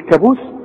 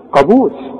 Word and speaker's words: قابوس: وعندك بقى في الكلمات البلد بتاعتنا بقى بنت المتداولة، عمرك ما تقول على القانون كانون، تقول كانون، قانون، وتقول قابوس، ما تقولش ايه، هتقول كابوس، قابوس، قرار، ها، قرض، خلاص.